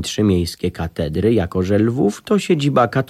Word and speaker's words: Lwów: Mój następny przystanek dorównywał wojenną sławą Przemyślowi, bo mało znany Lwów to polska wersja starego, dobrego Lembergu. Jeżeli Przemyśl wymawia się jak kichnięcie, to żeby oddać autentyczne brzmienie słowa Lwów, musicie naśladować szczeknięcie ujadającego głębokim basem mastifa.